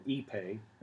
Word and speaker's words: Ipe: And that and the railings and the floor panels are all made of this nice, dense Brazilian hardwood called Ipe. It's spelled I-P-E.